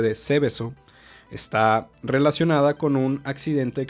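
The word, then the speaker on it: Seveso: Este segundo caso se trata, o más bien es llamado Cebeso, con S. C-E-V-E-S-O, Cebeso. Y es que Cebeso, Cebeso, perdón, con acento en la E, primera E.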